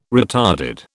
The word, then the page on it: retarded: British English